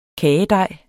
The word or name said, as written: kagedej